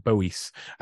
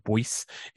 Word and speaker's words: Boies: And he may, mentioned a guy, David Boies, you you pronounce it apparently. Uh, most people pronounce it Boyce, and then people think it's David Bowie, so they get confused. But David Boies is one of the biggest um, uh, and most prominent solicitors in American modern American history.